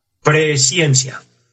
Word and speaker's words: presciencia: Quizás usted, como buen oyente, Aquí escucho una palabra que quizás cree que yo la pronuncié mal. No, así está escrita. Dice elegido según la presciencia.